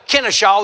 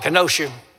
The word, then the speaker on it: Kennesaw: And we got down to Kennesaw. They call it Kenosha. I had to train them how to pronounce it.